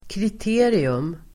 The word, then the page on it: kriterium: [krit'e:rium]